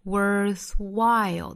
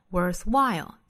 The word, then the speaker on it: worthwhile: 尾部的略掉 and perhaps you need to do need 尾部的略掉 you need to do something 后面 worthwhile 同样重读放到后面这个音节上 worthwhile 同样还要注意 worth 单词尾部呢是 th 的发音，舌尖伸出来去摩擦上排牙齿 worth 后面的 while 注意双元音 I 的发音不能丢了 I 后面紧跟舌侧音的动作 I'll while worthwhile You need to do something worthwhile with it.